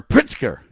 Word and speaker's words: Pritzker: I mean, Gov Fritzker, or Britzker, I guess it is, or Pritzker is how you say it.